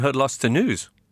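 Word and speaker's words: Het Laatste Nieuws: And you're from top Belgian publication, Hudlaster News. – Yeah, and you pronounced that well too, so... – Because it sounds like At Last the News, but that's not what it's actually called, is it?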